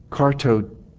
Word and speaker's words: katartizo: It is the Greek word katartizo, katartizo, with the long O at the end.